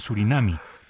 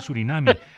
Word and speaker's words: Surinam: periodista dijo al aire que había llegado a la delegación de Surinami. Y le dije, eso es incorrecto, no se dice Surinami. Ay, sí, yo pensaba que yo también estaba como dudando, yo sabía que se decía Surinam.